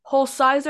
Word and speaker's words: Hulsizer: But before we do this, is it Hulsizer or Hulsizer? How do you say your name? – Yeah, Hulsizer, just like it's spelled, like H-U-L and then Sizer.